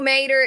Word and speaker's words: Mater: It's pronounced like toe Mater, except without the toe.